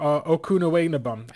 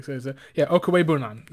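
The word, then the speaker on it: Okwuegbunam: Albert Okunewanabum, I think that's how you pronounce it.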